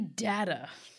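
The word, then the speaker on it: Dagda: So we're starting with the data, which is, we looked it up. – We did. – Yes, that's how you pronounce it.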